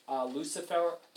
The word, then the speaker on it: Lucifero: Lucifero is how you would say his name in Italian. Lucifero, I suppose.